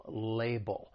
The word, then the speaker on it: label: Now, for pronunciation, the word has two syllables. The stress is on that first syllable, label. The other thing I'd like to point out is the second syllable, it ends with that dark L, which is that ull sound. And then if we're following it with the word as, I told you that's a common phrase, well then we can link that dark L with the beginning vowel sound in as. Label as, label as.